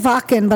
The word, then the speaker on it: Wacken: It is Wacken, but I don't expect you to know how to pronounce that. – Oh, it's Wacken? – It's Wacken. – Wacken, I'm sorry. – Don't be sorry. It's a German word.